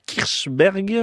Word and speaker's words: Kirchberg: On atterrit là dans le quartier plus moderne du Kirchberg.